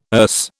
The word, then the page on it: Erse: British English